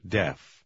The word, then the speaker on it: deaf: В номере 8 тоже произношение краткого звука E. Попробуйте прочитать. – I'm not deaf. Deaf.